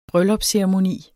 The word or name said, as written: bryllupsceremoni